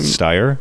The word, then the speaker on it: Steyer: Steyer.